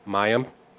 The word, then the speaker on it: Mayim: Mayim.